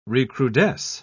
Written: recrudesce

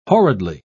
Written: horridly

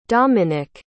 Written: domenick

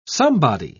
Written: somebody

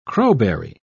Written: crowberry

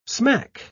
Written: smack

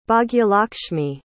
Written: Bhagyalakshmi

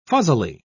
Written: fuzzily

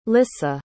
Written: lissa

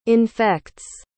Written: infects

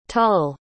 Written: tull

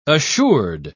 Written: assured

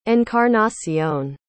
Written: encarnacion

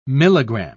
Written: milligram

